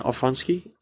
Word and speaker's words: Aronofsky: Darren Aronofsky.